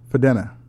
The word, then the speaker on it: Fudena: F-U-D-E-N-A? How would you pronounce that? Foodana? – Foodana. – Foodana? – Foodana. – Foodana. – I could be... – No, Foodana. We'll go Foodana.